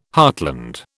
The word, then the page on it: heartland: British English